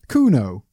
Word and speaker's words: Kuno: And I'm going to look up the pronunciation of Kuno, because he's the main character today. And I might not always look up the pronunciations, but I like to for the main characters. Kuno. I'm guessing it's a German name, because this is set in Nuremberg, in Germany. Kuno. Okay, Kuno. Kuno.